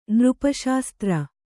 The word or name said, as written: ನೃಪಶಾಸ್ತ್ರ